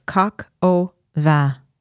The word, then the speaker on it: coq au vin: It's coq au vin.